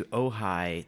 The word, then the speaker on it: Ojai: Last weekend, I went to Ojai. That's spelled O-J-A-I.